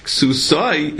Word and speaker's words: ksusai: So the Torah says both, to tell you, ksusai is how you pronounce it, it was written ksusa, to show that the Torah is talking about an ani who was so poor, he only had one garment for both.